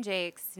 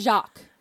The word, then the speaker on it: Jakes: Brian Jakes' name is pronounced as, Jakes rhymes with makes. – There you go. – So there you go. – You should know that the British man refuses to pronounce his last name the French way. Because it should be Jacques.